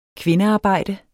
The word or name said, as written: kvindearbejde